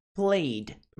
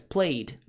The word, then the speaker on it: played: Played, played, pronúncia played, não é played. Não pronuncia id, e sim d.